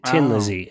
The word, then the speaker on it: Thin Lizzy: They spelled it Thin Lizzy, but they pronounced it Tin Lizzy in Dublin.